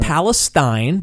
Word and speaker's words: Palestine: Palestine, not Palestine, but Palestine. – That's how they say it.